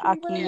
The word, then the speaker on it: ackee: learn to like a key. I think that's how you pronounce it, right? – yeah, Ackee, yeah. Yes.